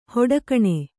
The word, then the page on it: ಹೊಡಕಣೆ: hoḍakaṇe